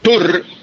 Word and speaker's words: Tour: T-O-U-R. – Don Carlos Julio, la palabra Tour, escrita T-O-U-R, pronunciada Tour, es una palabra francesa que se emplea para nombrar la competencia de ciclismo que se hace en Francia.